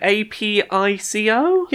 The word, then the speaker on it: Apico: Uh, yeah, I'm not sure how it's pronounced, but A P I C O? Yeah, I'd say Apico because I'm thinking um, uh, apiary.